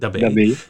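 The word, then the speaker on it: Prix de l'Abbaye: Kortere afstand is de Prix d'Abbé. Nee, hoe spreek je dat uit? – Doe jij maar. Ja, ben Dabé. Ja, dat is die sprint die, die, als je op de baan bent, die zie je dus ook niet.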